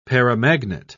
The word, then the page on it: paramagnet: British English